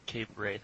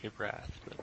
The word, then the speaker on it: Cape Wrath: I've never heard of Cape Wraith. I've heard of Cape Wrath. But. Anyways, I thought maybe it was some British, thing with the pronunciation there.